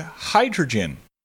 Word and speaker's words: hydrogen: Our first word, hydrogen. Hydrogen has a long I sound at the beginning, and the schwa sound is at the end. Hydrogen.